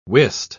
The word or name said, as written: wist